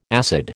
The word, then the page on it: acid: American English